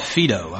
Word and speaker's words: Phaedo: But Plato wrote some of his philosophy in a work called, and I had trouble pronouncing it, I think it's called Phaedo. I finally looked up online this morning how to pronounce that. P-H-A-E-D-O.